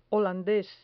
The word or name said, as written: holandes